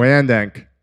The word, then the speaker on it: Wyandanch: Wyandank. – We got it. Do I make fun of you and you pronounce words wrong? No, I'm saying that's the way it should be pronounced. Wyandot? Wyandank.